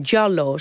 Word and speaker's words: Giallo: Eh, allereerst die naam, Giallo, zeg ik het goed? – Zeker, ja.